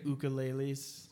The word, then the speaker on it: ukulele: Did you just say ukuleles? – Ukulele. – Trying to be all proper? – If you want to pronounce it proper, it's ukulele.